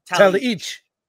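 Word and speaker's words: Talajić: He told us it was Talaich.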